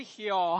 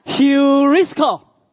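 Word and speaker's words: Heurisko (εὑρίσκω): 跟我来念一遍，Heurisko。哎，发音正常一点的，不是 heo 哈，Heurisko。就是寻得这个字在希腊文的发音。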